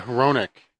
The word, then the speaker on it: Hronek: Um, yeah, it's like uh, Ronick. – H R O N E K, but the H is sometimes pronounced as silent.